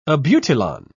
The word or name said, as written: abutilon